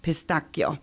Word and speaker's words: pistachio: They're putting pistachio, which, by the way, over there, they pronounce it pistacchio.